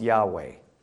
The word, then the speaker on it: Yahweh: That's the way our English Bibles translate the Hebrew word that we think is pronounced Yahweh.